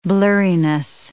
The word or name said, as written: blurriness